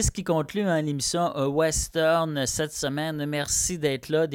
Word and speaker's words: Ouesteurne: Ça s'écrit O-U-E-S-T-E-U-R-N-E, Western.